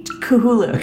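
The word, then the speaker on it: Cthulhu: Kahulu. – Kahulu.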